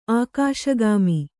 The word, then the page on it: ಆಕಾಶಗಾಮಿ: ākāśagāmi